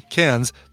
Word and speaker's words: Cairns: I know you're saying it's pronounced Cairns. Take it as an Aussie, Cairns. That's how you pronounce it.